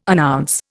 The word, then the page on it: announce: Indian